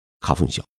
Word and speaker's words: 花粉症: 日文讲花粉症，念作是卡风小。